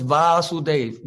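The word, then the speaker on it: Vāsudev: And then the Supreme Lord is known as Vasudev, V followed by a long A.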